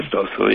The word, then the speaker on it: Maestoso: Maestoso, yes.